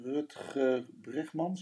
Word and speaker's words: Rutger Bregman: heb ik een boek gekregen van Rutger Bregman, zeg ik dat goed? – Ah, ja.